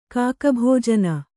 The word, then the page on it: ಕಾಕಭಾಜನ: kāka bhājana